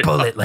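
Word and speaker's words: Bulleit: My wife's last name is Bullet, like the bourbon.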